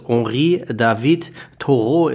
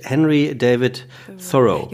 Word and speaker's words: Henry David Thoreau: dass es Henri David Thoreau ist. Mhm. Äh, aber es ist tatsächlich Henry David Thoreau.